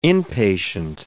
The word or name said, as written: inpatient